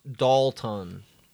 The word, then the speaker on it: Dolton: It's pronounced Dalton. – But it's spelled Dolt? – Yeah, D-O-L-T-O-N.